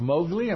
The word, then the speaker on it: Mowgli: He plays the role of Mowgli.